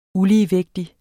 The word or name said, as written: uligevægtig